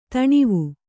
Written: ಧನ್ವ

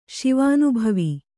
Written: ಶಿವಾನುಭವಿ

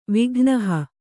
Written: ವಿಘ್ನಹ

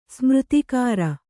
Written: ಸ್ಮೃತಿಕಾರ

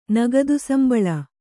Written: ನಗದುಸಂಬಳ

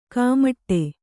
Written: ಕಾಮಾಟಿ

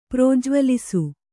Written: ಪ್ರಜ್ವಲಿಸು

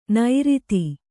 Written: ನೈರಿತಿ

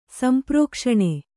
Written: ಸಂಪ್ರೋಕ್ಷಣ

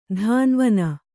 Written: ಧಾನ್ವನ

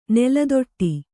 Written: ನೆಲತಾಟಿ

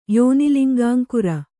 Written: ಯೋನಿಲಿಂಗಾಂಕುರ

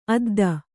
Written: ಅದ್ದನ

